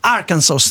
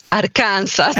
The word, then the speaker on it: Arkansas: Buongiorno e grazie per la pronuncia perfetta, anche perché io lo dico Arkansas.